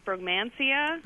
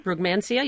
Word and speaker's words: Brugmansia: And then the second quick question is I have, I believe it's pronounced Brugmansia. – Brugmansia, yeah.